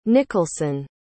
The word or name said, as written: nicholson